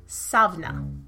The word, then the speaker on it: Samhain: If we're going to talk about the history of Halloween, let's go all the way back to the beginning, to Samhain, or as it is, we're hopefully correctly pronounced, Savna. It's Gaelic, and that's my best guess.